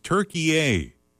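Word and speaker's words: Türkiye: T-U-R-K-I-Y-E or something. – Yes. – Like that. – It's pronounced Turkey A.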